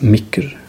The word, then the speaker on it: meir: Oder als letztes Beispiel das Wort für «Mur», die Mauer, schreiben wir «M-E-I-R», «Meir». Und es gibt Leute, die das «Micker» aussprechen.